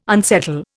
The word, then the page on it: unsettle: Indian